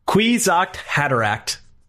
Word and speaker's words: Kwisatz Haderach: I thought in my head I heard Kawasach Hadarach. Kawasach Hadarach. – If I can see it, I can say it. Kwisatz Haderach. – Kwisatz Haderach. That's how it's pronounced there. That's how you just said it.